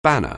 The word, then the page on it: banner: anglais